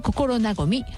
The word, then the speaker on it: 心和: で、納豆も山下納豆さんの納豆はいろんな種類があるんですが、受賞したのは心和み、漢字で書くと心和む、昭和の和ですね、心と昭和の和を合わせた漢字二文字なんですが、心和みと読みます。